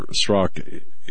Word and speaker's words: Strzok: Thank you, because I keep wanting to pronounce the Z in there. This Peter Strzok,